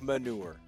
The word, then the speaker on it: Manu: manure, okay? – But you can also say it like Manu is also emphasis on the U.